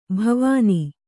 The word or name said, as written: ಭಾವನೆ